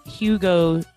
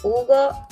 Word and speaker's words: Hugo: It will be Hugo Chavez. – So, Hugo without the H, so no Hugo, okay? – That is correct, yes.